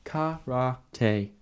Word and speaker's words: karate: Karate. 3 syllables.